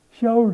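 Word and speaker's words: Shaul (שאול): En Saulus, eigenlijk Shaul. Hè, jij kunt het misschien beter uitspreken dan ik. – Shaul. – Ja.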